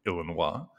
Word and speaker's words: Illinois: on my last visit in the great state of Illinois, or as the locals say, Illinois, I tried Lucky Charms for the first time.